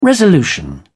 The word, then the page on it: resolution: British English